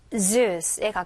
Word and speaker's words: Zeus: 우리는 제우스라고 하지만 영어식으로 읽을 때는 이제 Zeus에 가깝게 발음이 되어 들릴 거예요.